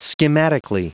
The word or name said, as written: schematically